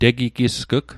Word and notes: tegigisg'g: Mi'gmaq

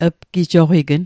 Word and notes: pgijoqign: Mi'gmaq

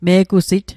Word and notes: me'gusit: Mi'gmaq

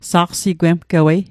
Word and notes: saqsigwemgewei: Mi'gmaq

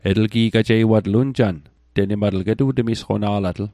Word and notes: temisqona'latl: Mi'gmaq

